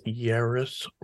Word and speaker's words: Yahraes: The way my dad used to say it, which would be embarrassing, was Yahraes rhymes with Paris.